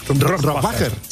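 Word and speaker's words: Rohrwacher: Bueno, ¿cómo se pronuncia Alba Rohrbacher-Cochet? – Rohrbacher. – Rohrbacher. – Sí.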